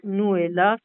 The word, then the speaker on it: Nouaillhat: Et on rappelle donc qu'on retrouve toutes les informations sur le site Alexis Nouella, ça s'écrit Nouaillhat, pour commander.